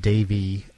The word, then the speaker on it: Devi: And you can check them out at Davey, which is spelled D-E-V-I.